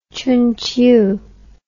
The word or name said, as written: Chunqiu